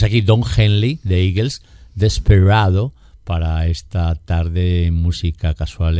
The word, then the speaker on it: Desperado: es desesperado, pero bueno. – Es desesperado, pero Desperado es como lo pronuncia Eagles.